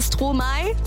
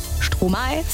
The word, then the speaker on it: Stromae: Aber ich habe zwei Eselsbrücken bei Stromae. Denken wir ab jetzt an Stroh im Mai. Stromae, Stromae, können wir uns merken. Und bei Tuvalu ist es Voulez-vous.